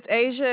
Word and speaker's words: Asia: My name is Asia. It is spelled A-S-I-A. – A-S-I-A.